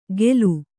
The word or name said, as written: ಖೇಲು